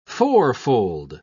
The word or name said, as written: fourfold